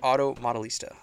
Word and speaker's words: Auto Modellista: Talk to us about Auto Modellista.